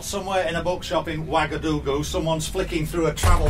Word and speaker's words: Ouagadougou: And it's pronounced Ouagadougou.